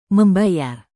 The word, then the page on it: membayar: Indonesian